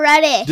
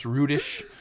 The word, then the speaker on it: Rudish: Paul, you said Paul Reddish. – Did I say Paul Reddish? I've been so good for the last few weeks since we realized it's pronounced Rudish.